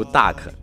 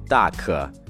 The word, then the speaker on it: duck: 我就知道你要读 duck，你可千万不要再这么读了。首先，这个词在发音上，它的元音是一个倒 v 的，呃，在美音中，这个音不读作啊，读作呃，你的嘴巴是相对放松的，所以说读作 duck，duck，并且最后的 k 的音特别的轻，不要读成 duck，而是 duck。